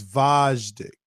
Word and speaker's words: Vajdic: Oh, it's Vajdic. – Yeah, like a V, like Va. – And it's almost like Dick.